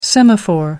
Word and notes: semaphore: American English

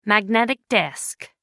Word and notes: magnetic disk: American English

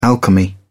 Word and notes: alchemy: British English

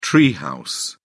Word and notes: tree house: British English

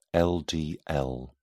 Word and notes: ldl: British English

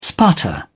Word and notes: sputter: British English

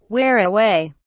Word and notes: wear away: British English

